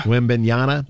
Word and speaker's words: Wembanyama: Wembanyama.